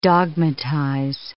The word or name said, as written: dogmatize